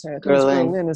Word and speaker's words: Ghislaine: Ghislaine is the way it's said, it's pronounced.